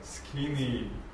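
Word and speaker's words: Skene (σκηνή): Betonung auf dem letzten, auf dem zweiten Eta, Skene.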